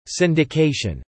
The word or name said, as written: syndication